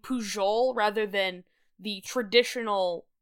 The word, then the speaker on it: Pujol: I'm using the Catalan pronunciation of his last name rather than the standard international Spanish pronunciation of his last name, because he is, of course, a proud member of the Catalan tribe, but also because his name pronounced in a normal Spanish accent would be Pujol.